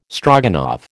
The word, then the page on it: Stroganoff: American English